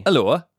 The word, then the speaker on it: Alloa: Alloa.